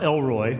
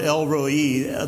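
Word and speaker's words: El Roi: It's pronounced Elroy, which I think is really funny, but it's a compound word, Elroi, the God who sees.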